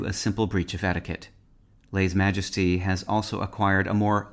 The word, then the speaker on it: lèse-majesté: In modern French, lèse-majesté is pronounced lèse-majesté.